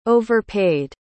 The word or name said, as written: overpaid